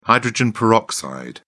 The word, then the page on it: hydrogen peroxide: British English